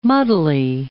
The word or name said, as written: muddly